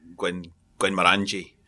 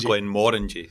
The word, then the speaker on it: Glenmorangie: They say Glenmorangie as well, but it's Glenmorangie. It's because of the word more. More means big.